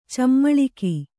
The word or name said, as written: ಚಮ್ಮಾಳಿಗೆ